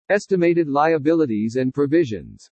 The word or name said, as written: ESTIMATED LIABILITIES AND PROVISIONS